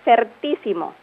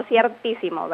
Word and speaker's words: certísimo: También debemos saber que su adjetivo superlativo es certísimo, no ciertísimo, ¿verdad? Porque también lo pronunciamos así, certísimo.